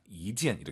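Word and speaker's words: 이젠 (一件): 이젠, 숫자 1이 2성으로 바뀌어야 돼요. 뒤에가 4성이니까 숫자 1은 2성입니다. 이젠 이렇게 가야 됩니다.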